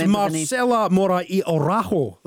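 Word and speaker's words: Marcela Mora y Araujo: It's Marcela Mora y Orajo. That's who we're being joined by, the Argentinian journalist. What about that for pronunciation, Stuart? – That was very good.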